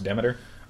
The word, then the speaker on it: Demeter: I always called her Demeter, but apparently it's pronounced Demeter.